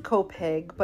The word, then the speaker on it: Copake: So it's pronounced Copaig, but it's spelled C-O-P-A-K-E, but locals will know pronounced Copaig.